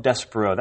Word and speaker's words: Despereaux: Tale of Despereaux. That's a funny word. – It looks a bit French to me, Despereaux, yeah. – Because of the way it's spelled. It ends in an A-U-X.